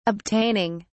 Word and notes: obtaining: British English

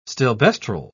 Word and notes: stilbestrol: British English

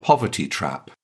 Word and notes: poverty trap: British English

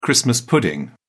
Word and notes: christmas pudding: British English

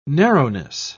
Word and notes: narrowness: British English

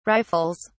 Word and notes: rifles: British English

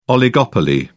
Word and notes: oligopoly: British English

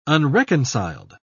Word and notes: unreconciled: British English

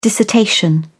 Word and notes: dissertation: British English

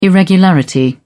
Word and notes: irregularity: British English

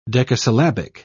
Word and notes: decasyllabic: British English

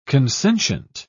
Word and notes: consentient: British English

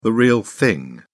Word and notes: real thing: British English

